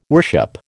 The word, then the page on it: worship: American English